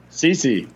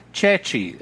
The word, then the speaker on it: Ceci: Ceci. – Incorrect. And this one is called Cody Cechi, is the way it's supposed to be pronounced.